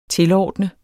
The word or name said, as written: tilordne